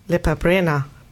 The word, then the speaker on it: Lepa Brena: Nämlich Lepa Brena. Habe ich es richtig ausgesprochen eigentlich? – Ja.